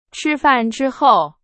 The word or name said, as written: 之后